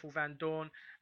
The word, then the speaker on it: Vandoorne: You know, Stoffel Vandoorne, am I saying that right? – Stoffel Vandoorne.